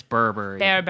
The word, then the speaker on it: berbere: Yeah, it's berbere. Yeah. – Yeah. – Correct pronunciation.